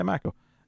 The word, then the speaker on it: Mako: Hunnam and Mako, like, or is that how you pronounce her name? Yeah, Mako. Mako Mori.